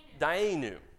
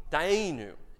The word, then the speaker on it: Dayenu: Can you say Dayenu? Dayenu. Thank you. That's a better pronunciation. What is it? What is it? – Dayenu. – Dayenu.